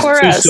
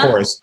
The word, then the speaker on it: chorus: There's course. We can say like, hey, this is the course I'm taking at school. Or you can say over the course of time. But then in the music, there's the chorus. So there's chorus. – Two syllables.